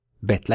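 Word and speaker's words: Bet (בית): Bet, like B, like the English B. Thing is that some letters, like this one, can actually have a different pronunciation. Be in Hebrew is also ve. Much like the Spanish language, ver, ver, to see. So it's something between B and V.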